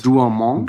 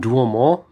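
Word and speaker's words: Douaumont: Duomont. Duomont.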